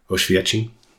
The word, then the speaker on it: Oświęcim: Oświęcim is how you say it in Polish.